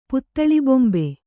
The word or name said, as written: ಪುತ್ತಳಿಬೊಂಬೆ